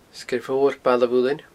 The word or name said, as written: Sgeir Mhor Baile a Mhuilinn